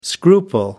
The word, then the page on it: scruple: American English